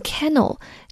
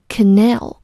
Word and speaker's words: canal: 我们来看它旁边的这个词 canal，注意这个词的发音，千万不要读成 canal，要注意重音在 n 的前面，所以要读成 canal。这个词做名词表示运河、水道，道是道路的道，不是吃饭的那个水道。同样呢，它在人体内也表示人体内的各种管道，所以 alimentary canal 就是消化道的意思。